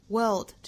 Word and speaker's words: world: world 这个词我们昨天早读也讲到过了哈，我们先发一个的发音，因为英音里是没有这个发音，所以即使单词内部含有字母 r，英音也不会去发一个卷舌的的发音，这里相对应的是一个长音的的发音，也就是 bird， b-i-r-d， bird 那个元音。